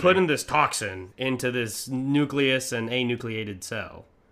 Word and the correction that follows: enucleated: Did I say that right? It's not anucleated. – It's enucleated.